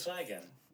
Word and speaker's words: flagon: And it's pronounced flagoon. Let's hear what it says. – Flagon. – Oh, it's called flagon.